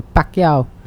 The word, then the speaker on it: Pacquiao: A Pacquiao. – Yes, that's the one. – That's the one. – Nice. – Nailed it. The pronunciation, chef's kiss.